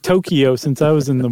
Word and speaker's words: Tokyo: Or I should say Tokyo. I've been saying it wrong.